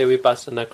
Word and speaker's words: Vipassana: Again, penultimate syllable, sa. Right? If we split this up, it's vipassana. Penultimate is short, so So no matter what this one is, we give the stress to it.